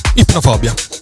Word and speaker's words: Ipnofobia: Ipnofobia. Ho detto bene? – Ipnofobia. Ah, ok. – All'americano. – L'accento, va bene, l'accento, non lo vedevo quindi va bene.